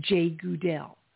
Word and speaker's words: Jegudiel: J-E-G-U-D-I-E-L. And it's pronounced Jay Goodell.